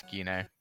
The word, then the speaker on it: Gine: Gine, that's how it's pronounced? – Yeah, it's Gine.